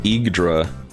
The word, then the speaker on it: Yggdra: I think it's pronounced Yggdra. – I put it on Google Translate and it said Yugdra. – Yugdra. – Well, I'm sure that it's not a real word, but it's like Yggdrasil, you know? – I guess?